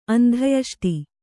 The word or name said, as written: ಅಂತ್ಯೇಷ್ಟಿ